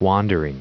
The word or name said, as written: wandering